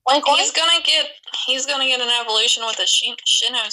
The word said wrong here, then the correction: Sinnoh: I think it's Sinnoh. – How do you say it?